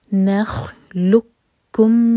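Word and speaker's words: नखलुकुम (نخلقكم): अलम नखलुकुम मिन, लाम के बाद जो कॉफ है वो है एम्प्टी और इसके बाद जो कैफ है उस पर है शद्दा, तो यहाँ पर कॉफ को बिल्कुल भी प्रोनाउंस नहीं करेंगे, लाम को हम कैफ से जोड़ेंगे.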